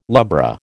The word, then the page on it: lubra: American English